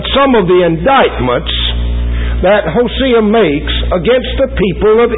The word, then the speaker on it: Hosea: The actual correct pronunciation of that prophet is Hosea. So if I call him Hosea tonight, now, I'm not trying to get highfalutin, but that's just the right way to pronounce it.